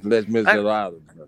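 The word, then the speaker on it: Les Misérables: Yeah, you got class if you've pronounced the title correctly. – All right. – It's Les Miserables.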